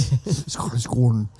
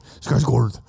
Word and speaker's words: Skarsgård: Skarsgård. Skarsgård.